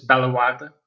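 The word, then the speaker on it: Bellewaerde: It's Belwadi, but yeah.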